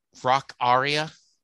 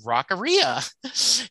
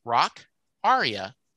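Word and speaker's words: Rockaria: And he says, do you know it's Rock Aria is the correct pronunciation? – And I went, know that.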